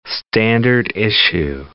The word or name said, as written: standard-issue